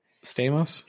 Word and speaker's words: Stamos: How do you pronounce his last name? – Stamos.